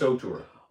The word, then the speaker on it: auteur: You know, but again, like, we're talking, so Denis Villeneuve is an auteur, how is it pronounced? – I think it's auteur. – Auteur, A-U-T-E-U-R, and you can tell because every shot, well, James did an NFT.